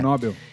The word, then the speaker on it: Nobel: É Nobel ou Nobel? – Nobel.